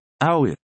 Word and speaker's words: hour: Exemplos em que o H é mudo: honest, honor, hour.